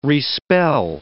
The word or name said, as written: respell